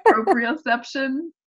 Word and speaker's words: proprioception: then, so this is, I'm not going to say it right because I've never tried to say it out loud before. I've only read it. Proprioception. – Yeah, okay, that's right.